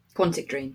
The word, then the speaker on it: Quantic Dream: By, I think it's Quantic Dream. Am I saying that right? Yeah. And Quantic Dream.